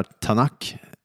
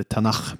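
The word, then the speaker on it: Tanakh: Det hebreer eller judar kallar Tanak, eller Tanach beroende på uttal då.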